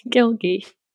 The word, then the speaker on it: Gilgi: It's fun to pronounce. Gilgi. It's like algae.